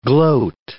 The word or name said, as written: gloat